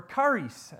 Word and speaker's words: charis: like the word charis, or charis, as it's probably more accurately pronounced, which means grace.